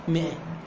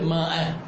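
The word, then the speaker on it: مائة: وان كان من علماء المنطق، وقد تعجب لو قيل لك ان 99% من الناس هم منطقيون، في المئة اخواني هاي الماء تكتب ماء تلفظ مئة، عندكم هاي المعلومة؟ خاف تقرأ الكتب، هي تكتب ماء بس تلفظ مئة، ما تلفظ ماء خلط باللفظ غلط،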